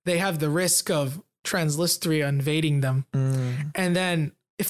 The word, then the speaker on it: Transnistria: This area is called Transnistria.